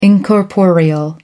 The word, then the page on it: incorporeal: American English